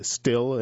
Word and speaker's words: Stihl: Orange County has seen an increase in industrial space between Steele, and I should mention that's spelled S-T-I-H-L, building out its new facility, Hi-Chu building a second manufacturing facility in Mebane, and Griffin Partners is building industrial space just outside Mebane.